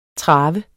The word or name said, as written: traver